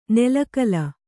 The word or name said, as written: ನಿಲಗಾಲ